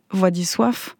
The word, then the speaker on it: Władysław: Et là, je le dis, d'ailleurs, c'est même Władysław, puisque c'est un W final, et le L barré se prononce « ouais » en polonais.